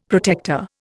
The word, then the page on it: protector: Indian